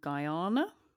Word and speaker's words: Guyana: May say this wrong, Guyana? – No, I think you said that right. – Good. – Wait, Ghana? – Guyana. No, it's Guyana.